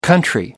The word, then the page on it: country: British English